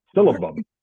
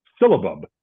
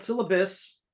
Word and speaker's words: syllabub: Syllabub. – What was it called? – Syllabub. – Syllabub, not syllabus, because I know you're a semi-professor.